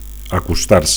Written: acostar

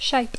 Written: shape

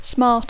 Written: smart

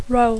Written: role

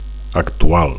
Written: actual

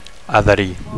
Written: adherir

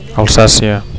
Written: Alsacia

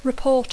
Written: report

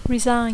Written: resign

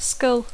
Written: skull

